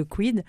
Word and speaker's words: CUYD: Alors, je vous propose maintenant d'écouter le titre Réveille-toi du dernier album Les Lumières sombres, donc de Quid, C-U-Y-D, ça s'écrit comme ça.